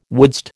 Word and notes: wouldst: American English

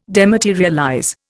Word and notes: dematerialize: Indian

